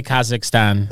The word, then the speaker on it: Kazakhstan: I don't even know how to say Kazakhstan. Did I say it right? – That was pretty good.